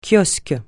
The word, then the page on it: kiosque: francais